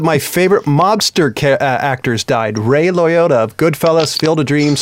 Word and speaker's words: Liotta: Liotta.